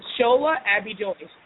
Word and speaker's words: Shola Abbey-Joyce: It's Shola Abbey-Joyce. – Oh, that's easy, just like it looks.